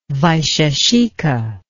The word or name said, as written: vaisheshika